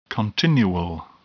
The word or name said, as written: continual